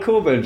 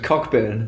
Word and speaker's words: Cockburn: Do you call people who are called Coburn just Cockburn? Yep. Yeah, that's how you spell it.